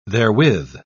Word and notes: therewith: British English

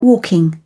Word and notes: walking: British English

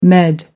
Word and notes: med: American English

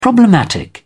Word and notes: problematic: British English

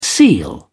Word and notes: seal: British English

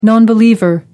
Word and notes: non believer: American English

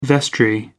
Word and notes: vestry: American English

